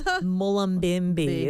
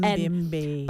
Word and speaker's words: Mullumbimby: I think it's Mullumbimby is how it's pronounced. – Mullumbimby. – Both my bestie from primary school,